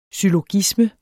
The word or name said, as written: syllogisme